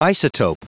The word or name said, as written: isotope